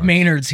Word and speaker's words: Menards: I went to Menards this morning. You know of Menards. I don't think you've been to a Menards, but we pronounce it Maynards here.